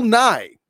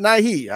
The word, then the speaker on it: Nye: It is Bill Nye. That's it. Not Nighy. I was I was wrong. It's just Bill Nye. Wow. Uh, the, the Y is silent.